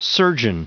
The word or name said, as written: surgeon